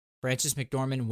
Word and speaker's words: Frances McDormand: I find it interesting that Frances McDormand win? Did I say her name right? Am I having another brain dead moment? – No, you're fine.